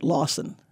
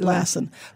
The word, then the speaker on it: Lassen: And he pronounced it Lawson. – Lawson. – We pronounce it Lassen, but he pronounced it Lawson.